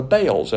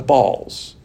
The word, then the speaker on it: Baals: But they've gone after idolatry, the Baals, as they call it, or you can pronounce it, the Baals.